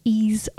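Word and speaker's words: Ys: It's spelt Y-S origin, but it's pronounced E's origin.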